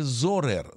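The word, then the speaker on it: Zurer: Зурер или Зурер? – Зурер. – Как правильно? Или правильно, стой, подождите, правильно же даже Зорер. Айелет Зорер. – Транскрипция пишется Зурер.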